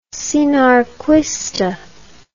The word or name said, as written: sinarquista